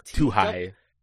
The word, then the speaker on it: Twohy: It's too high. – T-W-O-H-Y.